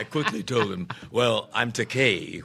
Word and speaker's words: Takei: He corrected the pronunciation of his name, but he then called me George Takei, a common mispronunciation of my surname because of the EI. They want to give it that Germanic Einstein, you know, pronunciation, mm-hmm. but it's the vowels in Japanese are pronounced like the Mediterranean languages, Italian, Spanish. It's Takei, E-I. However, there is a Japanese word pronounced Takai with an A, T-A-K-A-I, and that translates into English as expensive.